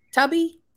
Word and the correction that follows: Tubi: whatever Tubi. – That. – Tubi, you say it right and respect it.